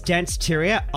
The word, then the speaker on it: Danceteria: Now, Danceteria. Have I said that right? I think it's Danceteria. – Oh, you are 100% correct. It's Danceteria.